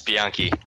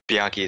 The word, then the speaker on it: Bianchi: It's Bianchi. – Bianchi. – Yeah, I know they pronounce it a couple different ways, but Bianchi is correct, yeah.